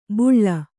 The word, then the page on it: ಬುಳ್ಳ: buḷḷa